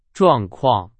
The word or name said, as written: 状况